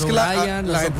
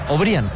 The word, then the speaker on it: O'Brien: No, no es O'Brien, O'Brien. – O'Brien. La gente cree que por cómo se escribe así, sí, se dice O'Brien. No, es O'Brien.